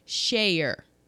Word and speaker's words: Schayer: Then we have Richard Schuyler. No, there's no L in it. Richard Schayer. There we go.